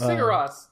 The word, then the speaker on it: Sigur Rós: Look, if you're going to do it, you can either do this, I'm not going to say this right, Sigur Rós version. – Sigur Rós. That's it. No, it's Sigur Rós.